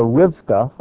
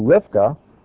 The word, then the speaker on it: Rivka: And Rebecca, or Rivka, I'll just short it, that's how her name is pronounced in Hebrew, Rivka, the mom, loved Jacob, who represents the neshama.